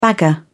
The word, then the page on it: bagger: British English